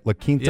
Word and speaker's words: La Quinta: Oak Creek police respond to the La Quinta. Is that how you say it? La Quinta? – Yes. Yep.